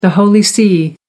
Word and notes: the holy see: American English

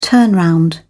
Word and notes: turnround: British English

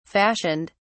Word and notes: fashioned: British English

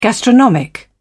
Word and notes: gastronomic: British English

